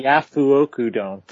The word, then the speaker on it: Yafuoku Dome: Don't say it the way it's spelled. – Yafu. It's the Yafuoku Dome.